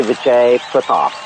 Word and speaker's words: Vijay Prathap: Yeah, it's actually Vijay Prathap.